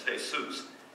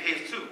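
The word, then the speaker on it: Jesús: Well, that's not how we pronounce J-E-S-U-S in Spanish. The U is a U sound. The E is a E sound. And the J always sounds like an H.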